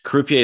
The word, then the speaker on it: Croupier: Is it Croupier? Is it French? Croupier. – That's right.